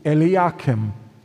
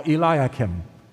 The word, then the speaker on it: Eliakim: Eliakim, the son of Hilkiah, or Eliakim. It can be pronounced either way.